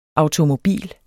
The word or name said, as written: automobil